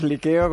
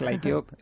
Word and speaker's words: Liqueo: Lo llamamos Liqueo, con pronunciación muy española. Cuando vamos a Estados Unidos lo americanizamos un poco, es Like-O.